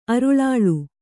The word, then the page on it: ಅರುಳಾಳು: aruḷāḷu